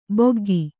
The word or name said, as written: ಬಗ್ಗು